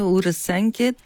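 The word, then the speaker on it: Urasenke: От сдружение Чадо Урасанке Танкокай България. И погледнах само, ме погледна. Първо, правилно ли е произношението и също така какво означава името на сдружението? – Ами, правилно е Урасанке Танкокай България.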